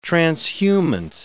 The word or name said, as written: transhumant